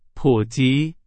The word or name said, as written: 普及